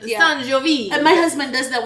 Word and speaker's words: Sangiovese: Sangiovese.